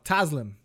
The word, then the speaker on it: Taslim: Taslim.